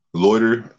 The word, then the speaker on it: Lotr: And by the way, y'all, that's spelled Lotr. – Loiter, in the sense of, it's like a play off the word loiter, where you're actually, like, you know, standing in front of somebody's business, handing out your own business cards and stuff like that.